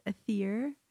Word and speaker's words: Atheer: Pronounced Atheer.